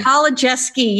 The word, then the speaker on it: Kolodziejski: And that really goes and reflects on my guest today, who is Chris Kolodziejski. Did I say that right? I always feel like I miss a syllable in there, Chris. – That's an impossible last name. It's Kolodziejski, but thank you, Bonnie. – Kolodziejski, yes, perfect.